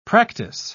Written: practice

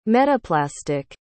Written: metaplastic

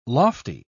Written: lofty

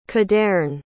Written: cadeyrn

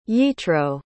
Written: yitro

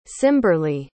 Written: cimberleigh

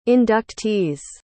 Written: inductees

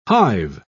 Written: hive

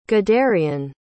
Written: gaderian

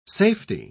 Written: safety

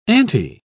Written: auntie

